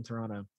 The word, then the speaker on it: Toronto: That's weird because you said you don't like to pronounce the second T in Toronto.